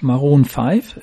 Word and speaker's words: Maroon 5: also das ist die Band Maroon 5, ist das so richtig ausgesprochen? – Das ist richtig, aber das ist ein Song von mir auch.